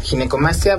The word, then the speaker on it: ginecomastia: Pues muchas gracias, y sí, definitivamente creo que es una, para empezar la palabra, le decía, ¿cómo se pronuncia? ¿Ginecomastia? ¿Ginecomastia? – Sí, es, exacto, ginecomastia.